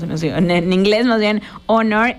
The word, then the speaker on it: Honor: Creo que Honor, sí, porque la H es muda en Estados Unidos, Honor.